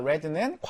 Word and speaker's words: read: 자, P가 나왔으니까 red는 과거 분사형 되겠네? 그치? read, read, read니까 red라고 읽을게요.